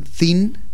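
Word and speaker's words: Zin: Pero aprovecho, ya que hablamos del tema, para recomendar el documental de Hernán Thin, sin con Z, por eso lo pronuncio así, que se llama La guerra contra las mujeres.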